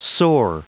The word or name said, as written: soar